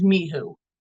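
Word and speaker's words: Mhuwe: So I want to talk about, I'm going to spell it first. It's M-H-U-W-E. And I believe the correct pronunciation is Mihu.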